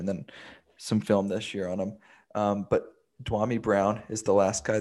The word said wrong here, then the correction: Dyami: Dyami? – Dyami Brown.